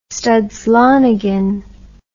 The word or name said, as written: Studs Lonigan